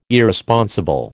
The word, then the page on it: Irresponsible: American English